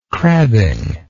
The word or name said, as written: crabbing